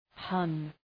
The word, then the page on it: pun: pʌn